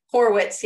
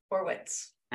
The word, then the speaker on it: Horowitz: Horowitz, yep. – Horowitz. No I in the middle there. It's just Horowitz. – No extra O, no I. Yep. Horowitz.